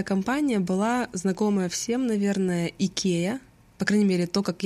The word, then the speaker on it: IKEA: Оказывается, эта компания в Швеции, где она и появилась изначально, произносится IKEA. То есть по-русски, можно сказать, мы даже ближе к оригиналу произносим это слово, чем американцы.